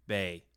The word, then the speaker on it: bae: Bay. – Bay? – Bay. – Like Michael. – But except for it's spelled B-A-E.